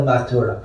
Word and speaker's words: Mathura: The magician Lord, the boy from the northern Mathura, because if you say Madura, and then Madura, Mathura in Tamil pronunciation, Madura, and then Madurai. So it actually means Mathura.